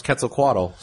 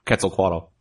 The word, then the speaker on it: Quetzalcoatl: how to pronounce Quetzalcoatl.